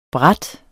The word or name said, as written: brat